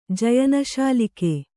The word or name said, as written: ಜಯನಶಾಲಿಕೆ